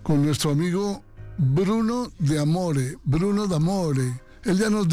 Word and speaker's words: D'Amore: Y entonces, mi apellido se pronuncia así como se lee, D'Amore, Bruno D'Amore.